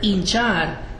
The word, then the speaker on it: hinchar: Because as I just said, the word hinchar doesn't sound like an H, does it? The H is silent.